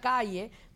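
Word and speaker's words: CAALE: Pero Adam es parte dueño de Cortaditos, también es ejecutivo de Google, pero él es uno de los fundadores de una organización que se pronuncia Calle, pero es el Cuban American Alliance for Leadership and Education.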